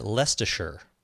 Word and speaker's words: Leicestershire: Next is Dave in Trumpton near Leicestershire. So this is the one we couldn't pronounce last time. – Right. – The town name. He sent a pronunciation, Dave did. Leicestershire.